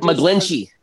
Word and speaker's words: McGlinchey: It's Mike McGlinchey.